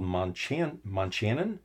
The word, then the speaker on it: Montchanin: It's called Monchanan, M-O-N-T-C-H-A-N-I-N. So I think that's how you pronounce it, is where the lovely Inn of Monchanan Village is based.